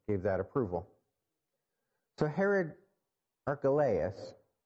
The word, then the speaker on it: Archelaus: Sometimes I hear it pronounced Archelaus, but Archelaus, Herod Antipas.